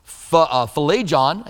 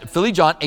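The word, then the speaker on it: Phlegon: And in 137, Philegon, I think that's how you say his name, said Philegon,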